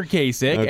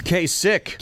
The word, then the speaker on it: Kasich: And another way to emphasize the right way to say his last name, because people tend to, and I've done it myself, say Kasich instead of Kasich.